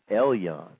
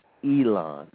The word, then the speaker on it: Elon: You know, I'm laughing because I keep trying to make sure I say her last name right, because I have a friend that last name is spelled exactly the same way, but it's pronounced differently. And so I keep wanting to say her last name, Elyon, but it's Elon, Miss Ayana Elon.